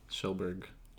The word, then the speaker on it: Kjellberg: It starts with a K. – Oh, really? – Yes. Schellberg. – Schnitzelbank. – It's spelled Kjellberg, if you were to say it, like, phonetically.